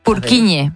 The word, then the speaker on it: Purkinje: Es que se escribe, en español sería Purkinje. – Purkinje. – Pero Purkinje es en checo, que es por porque es el apellido del descubridor.